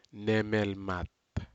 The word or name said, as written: nemelmat